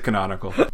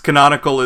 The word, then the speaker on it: canonical: Yes, you pronounce it canonical. Rog is just correcting me in VIM. Yes, it's canonical is the correct way to pronounce it.